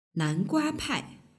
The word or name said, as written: nanguapai